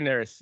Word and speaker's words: Neris: And Twins got to, do you pronounce it Neris? Is that Neris? – Hector Neris. Neris.